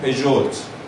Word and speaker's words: پژو: نوشته پژوت ولی میگن پژو خودشون هم میگن پژو نوشته رنالت ولی خودشون هم میگن رنو مثلا خب بعد اینا چیه؟